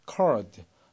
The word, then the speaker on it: card: 영국식 발음에서는 R 발음을 죽여서 발음합니다. 그래서 우리가 CARD라는 단어를 읽을 때 card, R이 들어가는 게 미국식이고 card 이렇게 들리는 것이 영국식이라고 보시면 됩니다. 미국 발음은 R 발음이 반드시 들리게 돼 있습니다.